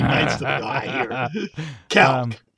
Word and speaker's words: calque: And the word is caulk. I tried to say it like you guys. – Yeah. – Caulk. Okay. – Caulk. – It's actually pronounced calc.